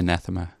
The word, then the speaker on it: Anathema: Anathema.